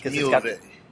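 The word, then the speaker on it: Miéville: Oh, wait, I can never pronounce his last, because I always want to say China. Melville, but it's not like... – It's Milieve.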